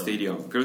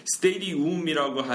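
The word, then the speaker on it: stadium: 근데 사실 맞는 발음은 스테디엄. – 그쵸. – 스테디엄. 그래서 그렇게 이렇게, 많더라고요. – 네, 그 잘못된 표기법 정말 많은데 스테디움이라고 하지 마시고요. 그냥 스테디엄.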